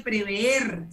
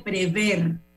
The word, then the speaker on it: prever: Y como uno aquí da docencia, Álvaro Alvarado, Álvaro Abdiel, eh, no se dice prever, se dice prever, para que lo sepas.